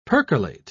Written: percolate

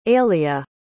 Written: ailia

